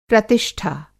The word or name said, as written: प्रतिष्ठा